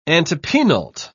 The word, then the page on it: antepenult: British English